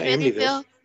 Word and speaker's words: Amityville: Andy Amityville. Hopefully I said it right. – Yeah, Amityville.